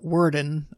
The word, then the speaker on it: Werden: Verden, or Werden. I'm pronouncing it the German way because I speak German.